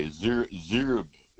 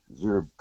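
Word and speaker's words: Xerub: There's a creek there, Zurb. It, I don't even know if that's how you pronounce it, but I think it's X-E-R-U-B, Creek, Zurb Creek.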